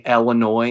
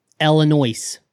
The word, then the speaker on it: Illinois: So technically, I mean, how does the English language tell us how to say Illinois? – No, it tells us that the S is silent, you shit.